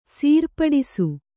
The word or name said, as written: ಸರಿಪಡಿಸು